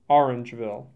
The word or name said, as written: Orangeville